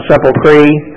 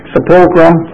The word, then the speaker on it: sepulcher: I don't know why, because nobody can pronounce sepulcher. They pronounce it sepulcre, sepulchrum.